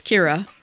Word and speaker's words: Kira: It's pronounced, you roll the R, so K-I-R-A. So if you can't roll your R's, it's Kira. But if you can roll your R's, it's Kira.